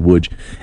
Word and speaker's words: Łódź: It's like L-O-D-Z, but it was, it's pronounced Łódź.